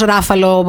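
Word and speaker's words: Ruffalo: Μετά Μαρκ Ράφαλο. Καλά το λέω αυτό. Μ' αρέσει αυτός ο ηθοποιός. Ράφαλο, προφέρεται.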